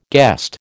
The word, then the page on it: guest: American English